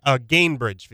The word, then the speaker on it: Gainbridge: So that will be their Champions Classic matchup in Indianapolis at Cambridge Fieldhouse, like I said. Gainbridge Fieldhouse, my bad. I pronounced that wrong.